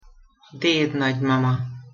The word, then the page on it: dednagymama: Hungarian